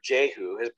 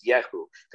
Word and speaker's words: Yehu: For he was commanded by a prophet, and Yehu, therefore, or Jehu, the right pronunciation is Yehu, because there's no J in Hebrew.